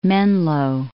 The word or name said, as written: Menlo Park